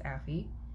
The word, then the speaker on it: Aoife: If you're listening to this and I'm pronouncing your last name wrong, I am very sorry, but it's narrated by first name, I think, pronounced Affie. A-O-I-F-E, and then last name is Mick, M-C, M-A-H-O-N.